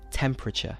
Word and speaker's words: temperature: So count the syllables. Temperature. I'm saying three. And the last syllable is... I don't pronounce the T. It's more of a CH sound. Temperature. But this is a very common thing that non-native English speakers will say temperature. Temperature. With four syllables.